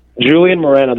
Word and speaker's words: Julian Morana: Julian Morana, that is correct.